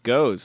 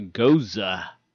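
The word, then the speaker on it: Gose: I thought it's pronounced goes, but it's actually goza.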